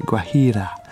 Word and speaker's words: Guajira: Okay, because I always say, I pronounce the J, but I shouldn't. It's just a silent J, but with a kind of H vibe to it. Guajira.